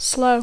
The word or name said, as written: slow